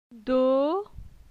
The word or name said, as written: Do